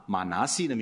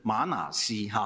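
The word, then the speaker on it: 玛拿西: 刚才我听读经员非常标准的念出马拿西的名字，我成长的时候一直把它念成马拿西哈，但是我怀疑它有两个，是不是有两个发音呢？因为我是习惯都是马拿西啊，或者是马拿西，容许我继续用马拿西好不好？因为这是我成长习惯的称呼。马拿西是约瑟的长子，第一个孩子。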